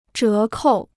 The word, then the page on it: 折扣: zhé kòu